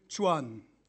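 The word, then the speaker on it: Chuan (船): Also Behälter heißt Chao, Person heißt Kao, 8 spricht sich Ba und Schiff heißt demzufolge Chuan. Ich hoffe, das ist einigermaßen richtig und nicht zu viele Chinesen hören mir jetzt zu. Chao plus Kao plus Ba macht Chuan.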